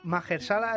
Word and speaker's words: Mahershala: tuvimos a Mahershala Ali.